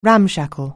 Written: ramshackle

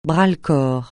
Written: bras-le-corps

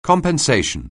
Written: compensation

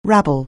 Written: rabble

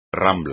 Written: rambla

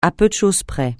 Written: à peu de choses près

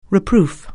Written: reproof

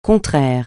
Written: contraire

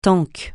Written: tank

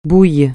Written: bouille

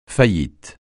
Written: faillite

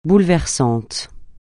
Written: bouleversant

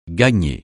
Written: gagner

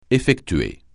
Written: effectuer

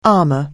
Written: armor

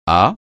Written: à

